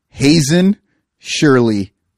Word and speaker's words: Hazen Shirley: Now, his real name is Hazen Shirley Kyler. That is his actual name. It's not spelled like this, but it sounds exactly like Hazing Shirley.